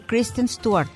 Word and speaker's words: Kristen Stewart: Y estaba diciendo de Kristen Stewart, ¿cómo se pronuncia? – Esa, así, tal cual.